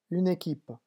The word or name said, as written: une equipe